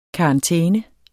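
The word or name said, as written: KARANTÆNER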